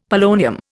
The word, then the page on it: polonium: Indian